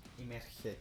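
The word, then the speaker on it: Immergé: Amerger, Imerger. Imergé, claro. O sea, O o Imerge, que como que lo pronunciamos. I M M E R G E con tilde.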